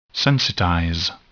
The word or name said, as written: sensitize